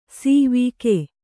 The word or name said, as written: ಸವಗ